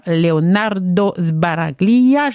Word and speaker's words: Leonardo Sbaraglia: Leonardo Sbaraglia, j'espère que je prononce bien son nom. – C'était parfait.